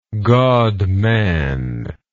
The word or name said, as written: godman